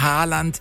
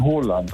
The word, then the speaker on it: Haaland: Erling Haaland ist falsch, ne? – Ja, das ist absolut falsch. Er wird ausgesprochen Haaland, also mit O. – Ja. – Haaland, und zwei A's werden als O ausgesprochen und ein A mit einem Kringel oben drauf ist eigentlich auch eine Aussprache, ein O.